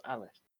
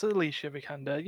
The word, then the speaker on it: Alicia Vikander: Alicia Vikander. – Oh, is it? I didn't know how you actually pronounce it. I thought it was pronounced as Alice. – It's Alicia Vikander.